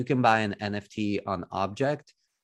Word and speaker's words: OBJKT: The other big thing about Tezos that really stands out to me is the NFT marketplace called Object, that is spelled OBJKT. So Object with funky spelling.